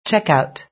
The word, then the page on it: checkout: British English